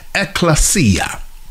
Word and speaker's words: ekklesia: The word church here in the Greek comes from the Greek word ekklesia. Ekklesia, which simply means assembly. For those of you taking notes, ekklesia is spelled E-K-K-L-E-S-I-A.